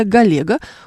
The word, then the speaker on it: Галега: Ульяна Ковальчук пишет, в школе учитель говорила, что фамилия Галега, ударение на «е», склоняется только у женщин.